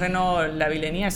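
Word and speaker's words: Renaud Lavillenie: el francés Reno Vilenía, se pronuncia así. Exactamente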